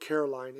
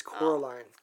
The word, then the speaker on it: Coraline: I always say it wrong. I always call it Caroline. It's Coraline.